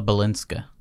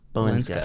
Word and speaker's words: Balinska: And then Keita, Jessica Alba, or Ella Balisca. How do you pronounce that? – Balisca? – The very last Balinska? – Ella Balinska. – Balinska.